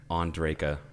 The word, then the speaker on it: Ondrejka: Dan Ondrejka. – Oh, and you said it right.